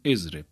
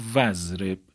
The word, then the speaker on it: اضرب: ابتدای کلام باشه خب تلفظ میشه، میگیم اضرب. اما اگر همین اضرب در وسط کلام قرار بگیره، مثلا قبلش واوی بیاد یا یه کلمه دیگه ای باشه که آخر اون کلمه رو بخوایم به اول اضرب وصل بکنیم، همزه این وسط میفته. مثلا همین واو رو ما بیاریم میشه وزرب یا سمزرب مثلا یا فزرب. این همزه دیگه تلفظ نمیشه.